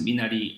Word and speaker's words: Minari: Minari, and how Koreans would pronounce it, Minari.